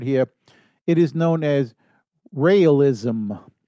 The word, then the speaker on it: Raelism: It's spelled R-A-E-L-I-S-M. Raylism. – Raylism.